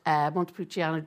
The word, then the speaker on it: Montepulciano: Ask Jean to find the best Montepulciano, I can never say that right, in Dublin. – Montepulciano.